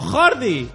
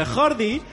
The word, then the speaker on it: Jordi: Si me asusté un poco el otro día que hablé con mi amigo catalán que tengo, mi amigo Jordi. Hombre, Mi- a veure, que la J no es pronuncia así en català.